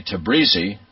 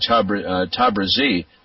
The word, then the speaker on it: Tabrizi: An American might say Tabrizi. I believe Iraj was saying Tabrizi. That name is spelled T-A-B-R-I-Z-I, T-A-B-R-I-Z-I.